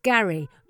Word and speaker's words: Garris: This is a legendary place about 5 miles outside of the Elfheim capital, Garris. Pronounced Gary. Gary. Okay.